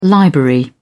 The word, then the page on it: library: British English